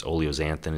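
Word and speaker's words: oleocanthal: But as far as the research goes, you know, from the oleic acid to, I believe it's oleoxanthin is how you pronounce the new compound that they're now talking about as a really good anti-inflammatory compound, anti-dementia, anti-Alzheimer's, you cannot beat olive oil.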